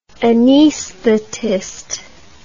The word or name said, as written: anaesthetist